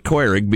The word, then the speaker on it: Keurig: So our uh, Keurig machine, or as my little brother pronounces it, Keurig, because he insists on the proper German pronunciation.